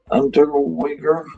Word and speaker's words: Unterweger: Jack Unterweger.